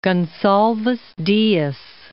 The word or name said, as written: Goncalves Dias